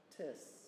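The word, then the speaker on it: tis (τις): The Greek word is a short, little three-letter word pronounced tis.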